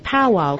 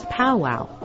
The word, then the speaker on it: powwow: In the 1800s, Europeans who observed these religious gatherings and dances mispronounced the word as powwow.